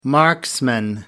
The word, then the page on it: marksman: American English